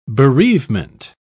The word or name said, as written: bereavement